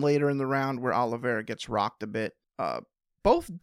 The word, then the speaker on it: Oliveira: Diana Babitza, rhymes with pizza, got the win over Maria Oliveira, rhymes with era.